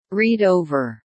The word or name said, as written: read over